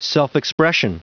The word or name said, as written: self-expression